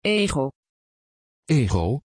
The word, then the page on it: Djego: Dutch